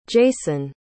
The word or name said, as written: jayceon